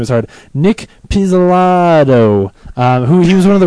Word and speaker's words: Pizzolatto: That's how he says, hi, you're talking to Nick Pizzolatto. Pizzolatto.